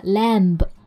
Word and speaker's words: lamb: 那我们在这个地方一定要特别注意 lamb 的这个单词的发音，虽然它的拼写是 l a m b，但是词尾的 b 其实是不发音的，不要读成的 lamb，应该是 lamb。